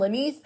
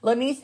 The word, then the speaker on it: Lynise: Also, you are more than welcome to join Jasmine Lanice Ministries, where you can find us on the GroupMe app. Just type in Jasmine Lanice. Lanice is spelled L-Y-N-I-S-E, and you can join.